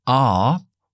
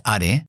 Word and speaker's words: are: Podemos decir are, esa es la pronunciación de a, de are, es una a larga. R. O si quieres el acento estadounidense, metes un poco la R, R, R.